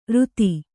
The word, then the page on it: ರುತಿ: ruti